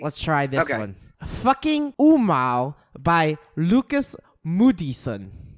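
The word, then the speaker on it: Åmål: When I said fucking Umau, Umau is spelled A-M-A-L. – I have no clue. – It's Swedish.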